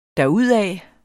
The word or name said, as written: derudad